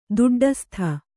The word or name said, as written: ದೊಡ್ಡಸ್ತು